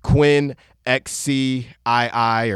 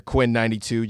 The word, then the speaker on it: Quinn XCII: Uh, on my upcoming list, Quinn XCII or Quinn 92, just much easier to pronounce it that way.